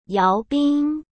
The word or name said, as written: yaobin